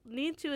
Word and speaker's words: Neetu: Need to is how you kind of say it in, like, in an English pronunciation. – Mm. – But if you said it with a Punjabi accent, you would say it Neetu.